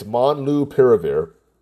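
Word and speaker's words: molnupiravir: There's a medicine from Merck called Monopiravir, Monlupiravir. Monlupiravir. Okay, I'm trying to, I kind of memorized how to say it. It's Monlupiravir.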